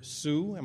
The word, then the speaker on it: Hsu: I got such a wonderful picture of this Taiwanese doctor named Dr. Su. – Dr. Su, yes. – Pronounced H-S-U,